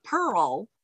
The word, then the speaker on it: Pirl: So Jackie is J-A-C-Q-U-I. And then yeah. Pearl, it's pronounced Pearl, but it's Pearl. P-I-R-L, like girl with a P.